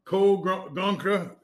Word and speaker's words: Cole Gonka: All right, this has been episode 1 of Catch, Cook, and Clean with our Canadian friend, Cole Gonka. Gonka, can you say your name right? – Okay, yeah, you got Cole Gonka.